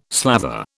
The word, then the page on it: slather: British English